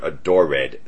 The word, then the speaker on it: adored: Yes, the word adored, three syllables.